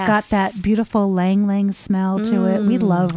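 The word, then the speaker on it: ylang-ylang: I thought it was Ylang Ylang. – I told you that. – You were right.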